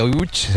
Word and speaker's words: Łódź: Pues vamos entonces a Łódź, si te parece, Enrique, para saber algo más de esta ciudad, aunque se pronuncia Łódź, se escribe Lodz, por si alguien lo busca. – Lodz, sí, Google, sí, no, casi no tiene nada que ver la pronunciación.